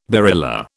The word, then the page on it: barilla: British English